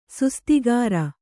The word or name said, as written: ಸುಸ್ತಿಗಾರ